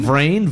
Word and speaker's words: Vrain: Vrain. V-R-A-I-N. It's like brain, but with a V.